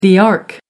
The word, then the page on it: ark: American English